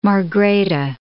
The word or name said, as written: Margrethe II